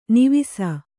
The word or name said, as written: ನೇವಸ